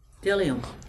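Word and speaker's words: bdellium: And then the way you pronounce that other word, you've dropped the B. The B is silent. – Oh. – I just taught you how to pronounce it. How would you pronounce it after you silence the D? – Dillium. – There you go.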